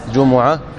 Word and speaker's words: جمعة: والجمعة تلفظ بضم الميم وبإسكانها، لغتان: جمعة وجمعة.